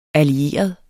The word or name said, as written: allieret